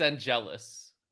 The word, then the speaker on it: Angelus: It's Angelus.